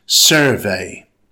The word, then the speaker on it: survey: It has two syllables. Stress the first syllable. It's pronounced survey.